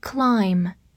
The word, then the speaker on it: climb: 这里呢有一个常常和 mountain 搭配的动词，叫做 climb，末尾的 b 不发音，意思就是爬。